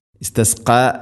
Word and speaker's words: استسقى: വ ഇതി വ ഇതിസ്തസ്ക വ ഇസി എന്നല്ല വ ഇതി വ ഇതിസ്തസ്ക ഇസ്തസ്ക ഖാഫ് കനപ്പിച്ചുച്ചരിക്കേണ്ട അക്ഷരമാണ്. ഇസ്തസ്ക ക അല്ല ക.